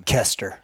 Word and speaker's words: Koester: Yeah, Kevin, how do I say your last name? – KOESTER. – Silent Kevin KOESTER.